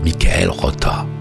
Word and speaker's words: Michael Rother: En allemand, il paraît qu'on prononce Michael Rotter.